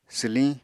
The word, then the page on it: swliñ: apatani